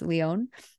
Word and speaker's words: Lyonne: I looked up how to say her name, by the way. It's Lyonne. Clea DuVall, Kathy Moriarty, RuPaul, Melanie Lynskey, Michelle Williams is in it.